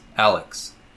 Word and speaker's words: Alyx: keep in mind, Alex is spelled Alyx. Alex.